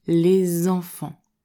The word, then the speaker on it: les enfants: Ici, la consonne « s » à la fin du mot « les » va se prononcer comme un « z » en français. On entendra « les enfants ».